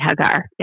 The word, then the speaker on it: Hagar: So we've been saying Hagar, you're saying Hagar. – Yes, that's how you say it, Hagar.